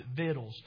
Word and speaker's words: victuals: It looks like victuals, but you pronounce it victuals.